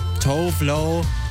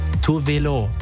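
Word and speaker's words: Tove Lo: Das ist für viele Leute Tovlo, aber eigentlich spricht man sie Tovelo. Ja, das wusste ich auch bis vor kurzem nicht, weil sie kommt ursprünglich aus Schweden und da sagt man das halt richtig so.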